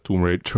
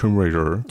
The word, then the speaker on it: Tomb Raider: Myslíte si, že kdyby neexistovala série Tomb Raider, Tomb Raider, Tomb Raider.